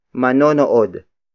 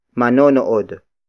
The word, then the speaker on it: manonood: Word for word, it's manonood sila ng basketball. Manonood sila ng basketball. Manonood sila ng basketball. The first word is manonood. Manonood. Manonood. Manonood is spelled as M-A-N-O-N-O-O-D.